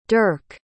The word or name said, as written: derk